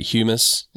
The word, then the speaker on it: humus: I don't know how to say it, humus. – Mm-hmm, mm-hmm. – Is that right? – Yes, that's right.